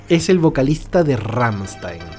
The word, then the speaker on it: Rammstein: Rammstein. Rammstein.